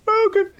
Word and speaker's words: Hadouken: Hadouken. Hadouken. You just kind of skip the ha. It's just like it's like in Spanish, the H is silent.